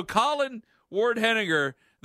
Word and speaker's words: Colin Ward-Henninger: Wait, so Colin Ward-Henninger, the guy who went to Quinnipiac, he said it wrong. – No, he said it right.